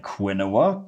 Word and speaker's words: quinoa: And in all fairness, it is kind of spelled like quinoa, Q-U-I-N-O-A. And it does, it's not exactly what you expect when you see that word quinoa.